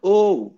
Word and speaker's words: OU: have, oh. – Chinese band O. It is Oh. – pronounced O. The OU is pronounced O.